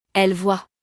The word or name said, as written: elles voient